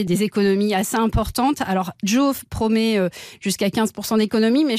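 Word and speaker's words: Jow: Cette application dont vous venez de nous parler, c'est Joe.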